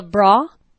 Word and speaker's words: Brough: All right, next question is from Carla Bra how would you pronounce that? B R O U G H. She's from Stafford, so it's obviously British. – Bra. – Bra. Mm-hmm. – Bra. – Sounds like a bra.